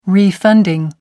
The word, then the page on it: refunding: American English